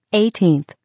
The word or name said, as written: 18th